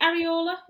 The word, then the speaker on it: Areola: Is it Areola? Is that how you pronounce it? – Yeah.